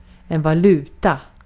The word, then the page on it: currency: /ˈkɝ.ən.si/; /ˈkɜːɹ.ən.si/